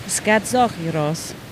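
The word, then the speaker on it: Skazochiros (σκαντζόχοιρος): Und ich versuche das jetzt mal so auszusprechen, wie man das aussprechen sollte. Und zwar heißt Griechisch auf Igel Skazochiros.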